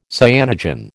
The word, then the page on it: cyanogen: American English